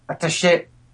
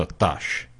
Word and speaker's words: attaché: E allora, ho detto, perché devo chiamare attaché in onore dei francesi? – L'hai chiamato attaché, hai solo spostato l'accento tonico.